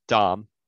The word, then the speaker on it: Damm: Owen Dom, spelled Dam, D-A-M-M, but pronounced Dom.